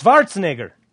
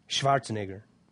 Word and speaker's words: Schwarzenegger: Why do I keep saying Schwarzenegger? What's the deal there? It's Schwarzenegger. Schwarzenegger. I'll try to pronounce it in the colloquial Austrian accent. Schwarzenegger. Anyway, I don't know why I got a mental block going with Schwarzenegger this year.